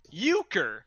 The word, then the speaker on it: Uecker: Bob Uecker, his last name is pronounced Euchre.